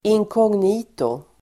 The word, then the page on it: inkognito: [ink'ång:nito]